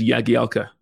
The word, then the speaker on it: Jagielka: He goes, I don't care, really, honestly, but the correct pronunciation is Jagielka, which then I did.